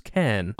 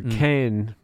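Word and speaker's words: Càrn: did he pronounce it Cairn? Either way, it's spelt C-A with an accent, R-N. Cairn, Cairn, Cairn. I think it was Cairn.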